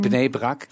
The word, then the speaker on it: Bnei Brak: Und dieser Anschlag gestern Abend, am Dienstagabend, der hat stattgefunden in Bnei Brak. Korrigier mich bitte, wenn ich das falsch ausspreche. – In Bnei Brak, genau.